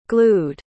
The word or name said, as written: glued